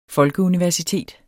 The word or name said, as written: folkeuniversitet